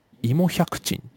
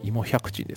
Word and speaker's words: 甘藷百珍: 芋百珍っていう、まあ、甘書百珍と書いて芋百珍ですね、